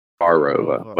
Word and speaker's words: Carova: If you go past Corolla, a place called Carova. That's spelled C-A-R-O-V-A. Carova, but it's Carova.